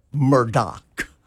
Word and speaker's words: Murdaugh: One of the most mispronounced names. It's actually spelled Murdaugh. – Right. – But it is pronounced Murdoch.